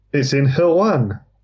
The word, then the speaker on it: Hirwaun: It's in Hirwan.